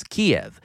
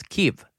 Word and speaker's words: Kyiv: Just a really quick example, Americans for decades, we've pronounced Ukraine's capital as Kiev, which is how you pronounce it in Russian. But in recent years, the Ukrainian government, and especially the Ukrainians themselves, they've been pushing the English language world to pronounce their capital as Kyiv, which is closer to how they say it in Ukrainian.